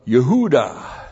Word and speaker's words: Yehuda: And then the construct of bane, the sons of, and the sons of Yehuda. Y, Shiva, H, long U, D, A, H. Yehuda, transliterated Judah.